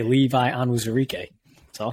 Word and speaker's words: Onwuzurike: Levi Onwuzurike. I think I'm close. How did I do? – That was sick, dude.